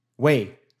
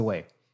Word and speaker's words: Weyl: Weyl. – This is the Weyl.